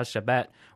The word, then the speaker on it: Shabet: The group's name, Dal Shabet, has a somewhat different meaning. Dal is taken from Dalda, which means sweet, and Shabet is the Korean pronunciation of sherbet.